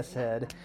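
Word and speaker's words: chesed (חֶסֶד): We really hammer this in our Psalter series, but the fundamental, it's in Hebrew, but the fundamental word underneath covenant is chesed, right? You kind of got to sound like you're hawking a loogie, you know, it's kind of like a chesed, right? That's how you say it.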